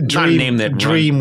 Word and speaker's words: D-Ream: It's a dream with punctuation in it.